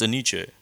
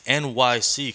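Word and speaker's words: Enyce: Yo, how do you pronounce Aniche? – You said it right. – Is that right? Because someone told me it's NYC, because E-N, N-Y-C, E-N.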